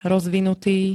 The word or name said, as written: rozvinutý